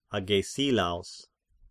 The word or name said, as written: Agesilaos